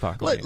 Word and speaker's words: Pacoima: Like Pacoignan. – Yeah, sure. Parent and Pacoignan. How do you say that? So that's where producer Wonderful. Hails from. Am I correct? – The great city of Pacoima.